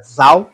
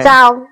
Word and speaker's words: Zhao: Eu aprendi que não é Zhao. – É ah, é Zhao que pronuncia? É, é Zhao. É Zhao.